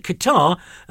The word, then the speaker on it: Qatar: They've already worked out that Qatar rhymes with bar, far and car.